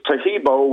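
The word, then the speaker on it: Taheebo: So the first word is Tejibo,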